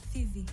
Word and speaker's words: Phivae: Fivi. – Fivi. Yes. Why do you spell it like P-H-I-V-A-E?